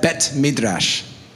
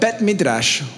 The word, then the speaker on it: Bet Midrash: Can you say that? Bet Midrash. You can't do it. You guys are scared. Ready? One, two, three. Bet Midrash. You have to roll your R.